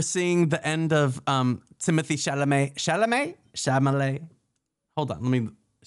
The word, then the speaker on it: Chalamet: Club Chalamet. How do you say it? – You got it.